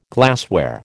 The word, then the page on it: glassware: American English